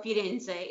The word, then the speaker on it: Firenze: And then, or Firenze is how you say it in Italian.